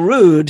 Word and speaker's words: Rude: His name is George Rude, R-U-D-E, with an accent on the E. His father was Norwegian. His mother was English. And so the name was Rude, okay, for the Norwegian.